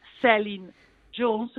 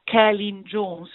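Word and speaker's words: Celyn Jones: La storia è stata diretta e scritta da Celine Jones, che si pronuncia Kellyn Jones, perché lui è un gallese, e da Tom Stern, l'ho scoperto anch'io, guarda, mi è entrato nell'orecchio perché l'ho sentito nominare.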